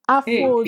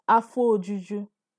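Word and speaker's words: Afọ juju: No, you see, the vowel elision is happening here. Yeah, so the O of the Afo is swallowed. So you're pronouncing, you're just merging it to the next word, which is Ojuju. So it's going to sound like Afo Juju.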